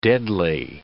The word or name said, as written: deadly